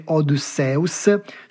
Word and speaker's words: Odysseus: Se preferite il nome in greco antico, dite Odysseus.